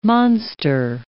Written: monster

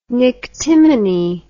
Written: nyctimene

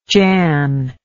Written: jan

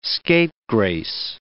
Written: scapegrace

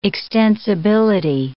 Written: extensibility

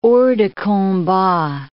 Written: hors de combat

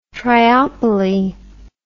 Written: triopoly

